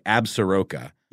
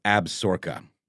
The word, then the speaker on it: Absaroka: going to backtrack and find the pronunciation and say how hey, i actually supposed to pronounce it but on paper it looks like absaroka Yep. But I believe it's Absorca.